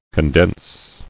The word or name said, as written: Condensable